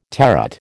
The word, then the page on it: terret: American English